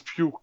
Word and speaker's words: Pugh: Ich glaube Pew, ne? – Florence Pew, glaube ich.